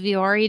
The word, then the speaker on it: Vuori: Vuori, I just learned how to pronounce this because they ended up hiring me to do some sponsored Instagram stories. And I had to refilm the whole thing because I called them Vuori. But it's V-U-O-R-I.